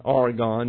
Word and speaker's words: Aragon: First of all, Columbus, believing that Asia could be reached by sailing westward, convinced the Spanish monarchs, King Ferdinand of Aragon, Aragon is the way it's pronounced, and Queen Isabella of Castile of the idea.